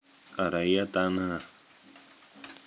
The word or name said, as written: ariyadhana